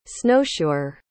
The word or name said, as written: snowshoer